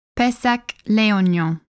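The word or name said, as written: Pessac-Léognan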